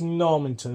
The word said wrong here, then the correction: Normanton: isn't it? – It's Normanton, yeah, in Wakefield.